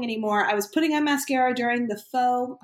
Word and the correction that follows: pho: voicemail or pho. It's pho. That's how you say it.